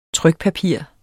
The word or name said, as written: trykpapir